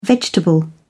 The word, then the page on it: vegetable: British English